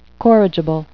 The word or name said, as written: corrigible